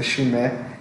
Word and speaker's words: Chimay: Chimay, né? Mas eu já ouvi falar que a pronúncia certa é Chimé.